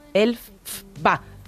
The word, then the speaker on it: Elphaba: Y que el nombre de Elphaba en realidad es... O sea, viene de la pronunciación del autor original de las obras del Mago de Oz, que es L.F. Baum. Elf-ba.